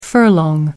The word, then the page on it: furlong: American English